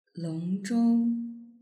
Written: longzhou

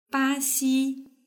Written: baxi

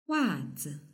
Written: wazi

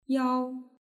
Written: yao

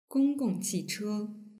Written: gonggongqiche

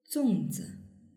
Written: zongzi

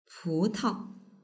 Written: putao

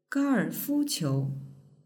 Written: gaoerfuqiu

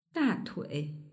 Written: datui

